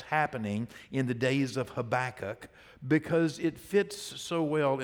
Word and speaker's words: Habakkuk: And we're going to be in the book of Habakkuk. Okay, H-A-B-A-K-K-U-K, if you have never known how to spell that. But it's Habakkuk.